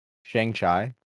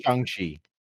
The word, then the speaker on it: Shang-Chi: Actually, the correct way to pronounce Shang-Chi is, I thought it was Shang-Chi. – No, it is Shang-Chi. – It is Shang-Chi. – Yeah, he says it in the movie.